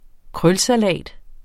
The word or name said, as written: varietet